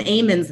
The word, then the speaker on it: almonds: I don't know the first thing about almonds, but apparently they pronounce them Amons there.